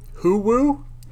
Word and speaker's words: Huwu: From overhearing a conversation, Morlinde, you can tell that this group has some sort of beef with an organization known as Huwu. – How do you spell that? – H-U-W-U.